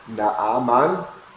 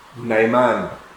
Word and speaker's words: Naaman: It's in the Old Testament, Naaman. In the New Testament, Naaman.